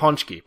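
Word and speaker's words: pączki: Punchki? Is that how you say it? – It's right? Punchki. Punchki. – Oh, Punchki? Okay.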